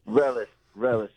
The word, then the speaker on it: Relis: Relis. It's like relish without the H.